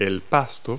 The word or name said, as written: grass